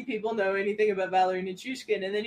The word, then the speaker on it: Nichushkin: Um, can I also shout out Petruskin? Is that am I saying that right? Petrushkin Val Nichushkin.